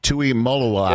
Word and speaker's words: Tuimoloau: You treated this segment like JT Tuimoloau treated Penn State. Did I say the name right? Tuimoloau, yeah.